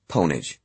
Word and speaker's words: Pwnage: Pwnage, Samuel says. Two syllables, rhymes with onage, but with a P.